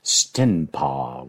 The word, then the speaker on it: Stenpa: That's spelled S-T-E-N-P-A, Stenpa.